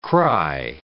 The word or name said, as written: cry